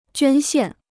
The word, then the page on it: 捐献: juān xiàn